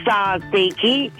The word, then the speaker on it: tzatziki: And I'm so glad you rang up with the correct answer because I wasn't quite sure on how it's pronounced, to be honest. – It is tzatziki.